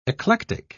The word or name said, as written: eclectic